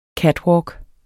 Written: catwalk

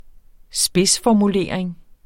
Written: spidsformulering

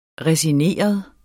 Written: resigneret

